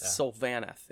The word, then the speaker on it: Sylvaneth: I always said Sylvaneth. It's Sylvaneth is the proper pronunciation.